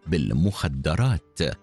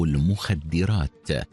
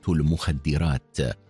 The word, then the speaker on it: المخدرات: فإذا جرى الحديث عن سموم الأعصاب وما يورث العقل ذل الاستلاب، فإن تلفظ بعضهم بالمخدرات بفتح الدال قول يجني على الصواب، والرشاد أن نقول تهريب المخدرات أو تجارة المخدرات بكسر الدال التي تنير الخطاب، فهي جمع اسم الفاعل مخدر.